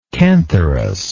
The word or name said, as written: cantharus